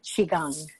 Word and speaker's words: Qigong: But it's called Qigong, and it's Q-I, and the second word is Q-O-N-G, but it's pronounced Qigong.